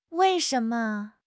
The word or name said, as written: wei4 shen2 me5